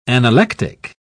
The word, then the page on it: analectic: British English